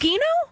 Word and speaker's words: Gugino: I think Gugino? Is that how we say it? Yes.